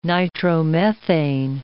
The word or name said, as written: nitromethane